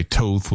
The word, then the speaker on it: Toth: And he simply goes by Toth with a long O there.